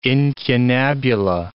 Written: incunabula

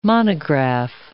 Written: monograph

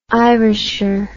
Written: irisher

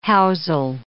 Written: housel

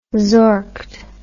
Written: zorked

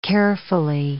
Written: carefully

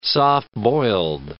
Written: soft-boiled

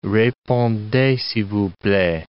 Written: repondez s'il vous plait

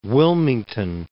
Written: Wilmington

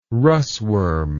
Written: Russwurm

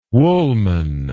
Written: woolman

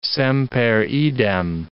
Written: semper idem

